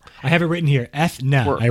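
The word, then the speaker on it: Eithné: Aethna, which is how they pronounced it on the stream, by the way, I know people have been saying Enya, but they did say Aethna.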